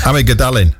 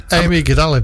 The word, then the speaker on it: amygdalin: Amygdalin. – Amygdalin.